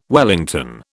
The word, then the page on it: wellington: British English